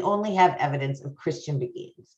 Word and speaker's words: Beguines: Yeah, Beguines.